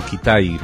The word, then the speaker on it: Kitaj: a Ronald Brooks Kitaj, o Kitaj, mejor pronunciado, porque él era estadounidense, pero de origen húngaro, y utilizaba el segundo apellido de su padrastro judío, que también...